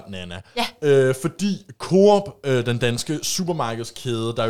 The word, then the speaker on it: Coop: Øh, det har jeg også godt hørt andre sige, men jeg er altså overbevist om, at det udtales Coop. – Ja, ja, du var bare, hvis nu der var nogen, der sad ligesom mig og kalder det Coop, så skulle I bare vide, at jeg har også.